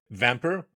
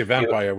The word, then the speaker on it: Vampr: As the co-founder and CEO of Vampr, am I pronouncing it right? I don't want to say vampire without Vampr.